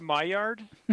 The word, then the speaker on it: Maillard: And I believe it's pronounced Maillard. – Oh, pardon me. Oh yeah. Oh yeah. That's right. It's French.